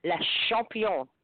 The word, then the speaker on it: champion: Say no, it's not champion. – Instead of ch. Don't say ch. Say sh. Can you say sh? – S-A. – Say it with me. Sh. It's le champion.